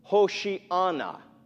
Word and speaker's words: Hosanna: And as Jesus approached, they laid their branches on the ground in front of him, shouting, "Hosanna!" Or more precisely, "Hoshi'ana," which means, "Save us now."